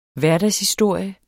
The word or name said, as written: hverdagshistorie